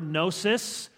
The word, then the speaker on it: gnosis: Comes from the Greek word gnosis, which has a silent G, just to be tricky, G-N-O-S-I-S.